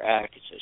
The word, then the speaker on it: Akatha: A-K-A-T-H-A. The T-H is pronounced like a hard T in higher Sanskrit. There's no TH the sound in that language. Therefore, the pronunciation is a hard T or ACRITA.